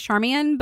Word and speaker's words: Charmian: Actually, probably something like, I think actually most people pronounce it Charmian, but I like look at it and my brain goes Charmaine. – So Charmin. – No, we shouldn't do that. We should pronounce names properly.